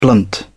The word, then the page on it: blunt: British English